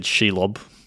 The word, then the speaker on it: Shelob: Yeah, I've always said Shelob.